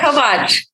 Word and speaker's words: Kovach: Yeah, Kovach. Kovach.